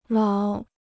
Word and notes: 老: lǎo

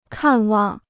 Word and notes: 看望: kàn wàng

